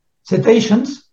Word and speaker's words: cetaceans: How do you say in English? – Cetaceans. Cetaceans. – Cetaceans. – It's cetaceans. Yeah.